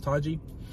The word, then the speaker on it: Taiji: You spell it Taiji, but it's pronounced Taji.